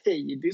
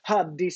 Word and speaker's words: Haddy: Nu ska vi se, Haddy, Nulle, han, han kritiserade mig hur jag uttalade hans förnamn där. Jag vet inte, Heidi sa jag eller något sånt där. Haddy Saleh Karim, ska vi säga så? – Jajamän.